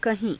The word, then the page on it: kahii~: Hindi